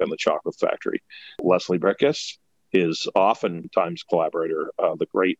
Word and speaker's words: Bricusse: Leslie Bricusse is his actual pronunciation of his name, but a lot of people call him Bricusse, so don't feel bad.